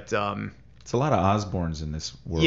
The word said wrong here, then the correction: Oberne: Yeah, no, I'm sorry, I said his name incorrectly. Just Oberne, no S in his name, but he, you know, he's kind of there's no getting around it.